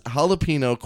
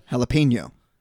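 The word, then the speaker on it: jalapeño: Jalapeno is the proper way to say it, but I say it my own way.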